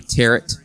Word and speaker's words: tarot: Father Dougal's seen a sign for tarot reading. – Or for those of you that pronounce it the other way, tarot.